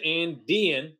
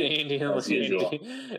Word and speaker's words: Andean: Ancient Andean. I'm putting too much emphasis on the D. I feel like ancient yeah. Andean. Ancient Andean, yeah.